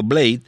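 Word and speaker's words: Blyde: El señor Gerardo Blade, se pronuncia así, ¿verdad? Es el apellido Blade. Blade.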